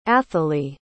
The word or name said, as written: athalie